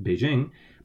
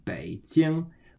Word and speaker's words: Beijing: That's actually the capital of China, which is in English, we'd probably pronounce it Beijing, but in proper Chinese, it's pronounced Beijing, which is B-E-I in the third tone, Bei, and then J-I-N-G in the first tone, Jing, Beijing. And then I say a word, Kao Ya, which is K-A-O in the third tone, Kao, and then Y-A in the first tone, Ya, Kao Ya means duck.